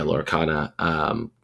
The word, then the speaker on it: Lorcana: Yeah, Lorcana.